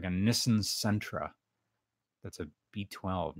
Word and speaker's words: Nissan: I know the correct pronunciation is Nissan. Nissan? Nissan. Nissan. It's it's like how we pronounce that one uh, noodle company who makes that ramen, Nissin ramen. Oh, yeah.